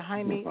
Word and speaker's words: Jaime: And actually, that's how his name is pronounced. I was calling you Dr. Jamie. I didn't know that the J was silent. So forgive me. You are Dr. Jaime Romo.